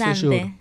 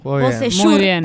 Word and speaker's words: Beausejour: Bonsejour, jugador chileno. – Jugó muy bien. – Beausejour también se pronuncia.